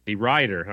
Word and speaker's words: DeRuyter: Tell me how to say your last name. – DeRuyter. – DeRuyter, huh? Like, do I say it with a T or a D? – There's a T in it, so I'd say T.